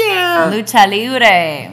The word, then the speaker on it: Lucha Libre: Lucha Libre. – Yes, that's how you say it.